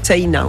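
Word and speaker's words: Seina: En fait, ça s'écrit un peu comme la Seine, mais... – Voilà, avec un A à la fin, Sena, on prononce le I aussi. – Comme si on avait un tréma au-dessus.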